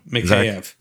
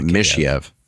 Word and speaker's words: Mikheyev: How do you say it? – Mikheyev. – It's not Mikheyev. Mikheyev.